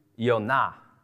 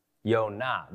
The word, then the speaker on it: Yonah: Jonah, we would pronounce it in Hebrew, Yonah. Yonah. They don't have J's. They have a Y.